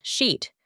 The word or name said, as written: sheet